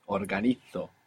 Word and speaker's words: organizo: I will just mention for anybody trying to keep up with the Spanish pronunciation that Z there is going to be pronounced Z. Organizo in Spain.